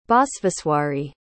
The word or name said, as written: basveswari